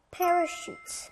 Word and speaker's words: parachute: Parachute. – 네, 낙하산 parachute. 여기도 슛 소리가 나요.